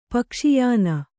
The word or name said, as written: ಪಕ್ಷಿಯಾನ